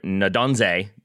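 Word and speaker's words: Nnadozie: I do apologize that I had her as Ndozie, and then John Strong called her Ndonze the entire game. And John Strong tends to work pretty hard on his pronunciation. So I'm going to go with Ndonze from now on.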